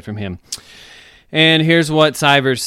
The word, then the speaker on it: Sivers: We have a great story from the book Hell Yeah or No by Derek, I think it's Sivers is how you pronounce his last name, S-I-V-E-R-S.